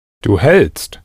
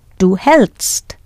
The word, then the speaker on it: hältst: Du hältst. – Du hältst.